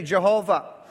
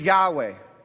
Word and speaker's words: Yahweh: When I was a kid, we used to say Jehovah. Nowadays, the popular opinion of how to pronounce this word is Yahweh.